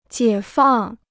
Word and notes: 解放: jiě fàng

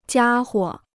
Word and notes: 家伙: jiā huo

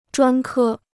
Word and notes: 专科: zhuān kē

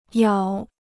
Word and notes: 咬: yǎo

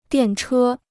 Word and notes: 电车: diàn chē

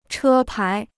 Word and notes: 车牌: chē pái

